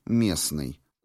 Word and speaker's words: местный: и вообще не обозначать никакого звука, «счастливый», «местный». Звук «Т» не произносится.